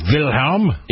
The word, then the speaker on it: Wilhelm: And I know that W's in Germany are pronounced like the letter V. That's why Kaiser Wilhelm. – Wilhelm. – Even though it's spelled almost like a weird bastardized version of William, it was Wilhelm.